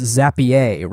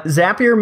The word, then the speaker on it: Zapier: It is it is definitely pronounced Zapier, right? Like it's super classy. yeah, the fancy version. Uh, Zapier makes you happier.